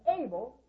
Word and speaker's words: Abel: Now that's Abel like ability. Now I'm going to change it, but it's still pronounced the same.